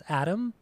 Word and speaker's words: Atum: So a few hours ago, the Smashing Pumpkins announced the release date for their brand new album called, I think it's pronounced Atom. It's spelled A-T-U-M.